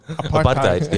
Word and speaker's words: apartheid: It blows my mind because we've been saying it wrong the whole time. Apartheid. It's like something you order from KFC, a thigh.